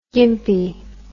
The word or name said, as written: gympie